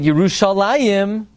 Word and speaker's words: Yerushalayim: Even though we pronounce it Yerushalayim, nevertheless, it's spelled without a Yud.